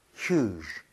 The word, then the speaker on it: huge: 그런데 이거는 상훈 씨가 약간 아쉽게도 발음을 계속 Hughes. 저번에 잘했는데 또 샜어요. 입 모양을 좀 오므려야 됩니다. – 아하. – 그래서 Hughes 아니고 끝까지 잡아주세요. Huge. Huge.